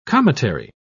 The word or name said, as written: cometary